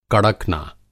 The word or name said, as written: कड़कना